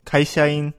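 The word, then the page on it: kaishain: Japanese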